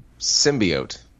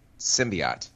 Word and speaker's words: symbiote: Symbiote.